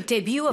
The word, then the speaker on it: debut: Okay, debut, of course, is French for first.